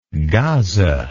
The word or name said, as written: gaza